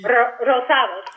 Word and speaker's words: Rosado: Rosado, sí.